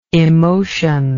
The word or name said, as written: emotionable